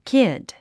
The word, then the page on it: kid: kíd; キッド